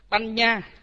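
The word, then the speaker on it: paññā: Rồi chữ anh, anh n có dấu ngã ở trên, là thí dụ như chúng ta thấy rằng chữ chữ banh, banh nha, chữ hai chữ anh n có dấu ngã ở trên, chúng ta thấy rằng nó là hợp âm, thì chúng ta đọc là Banh Nha.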